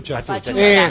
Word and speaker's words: pachucha: Y paso a presentar, si les parece, a mi colega, compañero de esta tarde, porque Agustina Díaz está ahora papucha, digamos, ¿cómo se dice? – Pachucha. – Eso, pachucha.